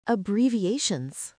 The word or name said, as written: abbreviations